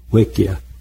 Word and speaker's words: Wickkie: The way they spell it on the rolls is W-I-C-K-K-I-E, but it actually is pronounced Wicca, Wicca.